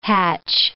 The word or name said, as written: hatch